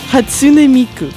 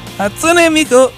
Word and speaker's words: Hatsune Miku: Hatsune Miku. – Hatsune Miku!